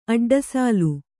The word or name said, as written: ಅಡ್ಡಸಲ